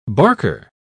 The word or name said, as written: barker